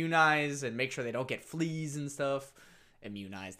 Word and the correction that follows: immunize: Immunize. – There you go. – I think you just put the syllable in the wrong spot.